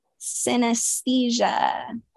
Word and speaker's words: synesthesia: Synesthesia.